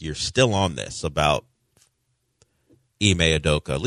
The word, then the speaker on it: Ime Udoka: Ime Udoka, in the middle of a six-game winning streak.